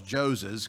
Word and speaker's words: Joses: They were James, Judas, Simon, and what I'm going to call is Joses, because it's spelled like Moses with a J.